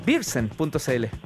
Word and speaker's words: beersen: No sé si lo registraste como marca registrada porque el, el, suena, eh, se escribe beer como cerveza en inglés. Sí. Pero, pero además suena como Pilsen, beersen.cl.